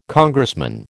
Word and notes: Congressman: American English

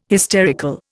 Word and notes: hysterical: Indian

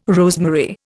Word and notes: rosemary: Indian

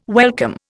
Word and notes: welcome: Indian

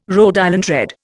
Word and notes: Rhode Island Red: Indian

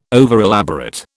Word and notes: overelaborate: British English

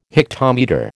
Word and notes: hectometre: American English

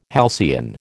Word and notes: halcyon: American English

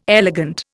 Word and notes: elegant: Indian

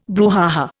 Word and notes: brouhaha: Indian

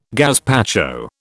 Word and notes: gazpacho: British English

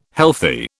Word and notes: healthy: British English

